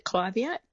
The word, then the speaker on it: Clivia: So first of all, I pronounce it Clivia, but it is a tomato, tomato.